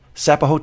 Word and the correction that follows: Sapo: The Sapo.